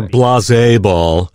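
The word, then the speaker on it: Blaseball: People think it's Blazeball, but it's spelled Blazeball. It's like baseball with an L. – Or blasé ball. It's blasé ball.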